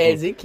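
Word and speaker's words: Schäl Sick: von der Shell Sick. glaube ich, hab ich habe es komplett falsch ausgesprochen. – Schelsig, ja. Schelsig.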